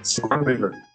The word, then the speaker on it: Sigourney Weaver: Sigourney Weaver.